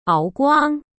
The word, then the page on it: 敖光: Áo Guāng